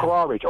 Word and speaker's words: Khawarij: Khawarij is spelled K, if I have it correctly, it's spelled K-W-A-R-W-A-I-J. Okay, these people are troublemakers. – Yeah, Khawarij, we call it in Arabic. – Exactly, yeah. Khawarij.